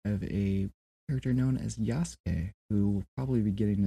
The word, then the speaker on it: Yasuke: Yasuke. – Yeah. – Derived from Isaac. Is how they pronounced Isaac. It became Yasuke.